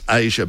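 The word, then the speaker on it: Aja: It's spelled and it's pronounced Asia, but it's A J A.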